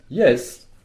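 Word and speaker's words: jes: Yes. On dit yes, comme en anglais, sauf que ça s'écrit J-E-S, c'est le J qui joue le rôle du Y anglais.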